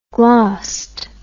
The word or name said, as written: glost